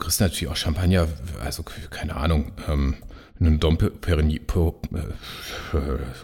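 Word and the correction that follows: Dom Pérignon: Genau, also wenn du ein Dom Perignon, wollte ich sagen, zum Beispiel nimmst, ja, ja dann, ja.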